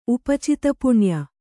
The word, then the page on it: ಉಪಚಿತಪುಣ್ಯ: kannada